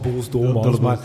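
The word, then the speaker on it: Boels Dolmans: Nou, ik ben er wel op aangesproken dat we toch de naam goed moesten uitspreken. Dus het is gewoon Boels Dolmans.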